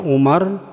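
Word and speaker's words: Umar (عمر): Kalau setelah tulisan apa itu mim apa ain mim dan ra itu ada wawu, ya, itu bacanya Amr atau Amrun. Tapi kalau enggak ada wawunya, ya, di belakangnya, itu dibaca Umar. Jadi wawu pada lafaz Amrun itu hanya sebagai tanda untuk membedakan mana yang dibaca Umar, mana yang dibaca Amr.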